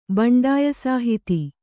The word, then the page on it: ಬಂಡಾಯಸಾಹಿತಿ: baṇḍāya sāhiti